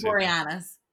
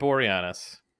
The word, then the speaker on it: Boreanaz: David Boreanaz. – David Boreanaz.